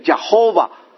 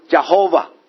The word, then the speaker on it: Jehovah: 所以我们可以做这样的一个结论，就是耶和华这个名字呢是造出来的，本来是没有这个名字的，本来的名字是叫做亚伟，可是因为加上别的发音的母音，把它硬加到这个亚伟底下去，才发音出来变成 Jehovah。